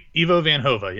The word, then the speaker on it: Ivo van Hove: Was it Ivo van Hove? Did I say that right? – Ivo van Hove, yeah.